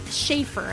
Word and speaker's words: Shafrir: Am I saying that right? – Shafrir, actually.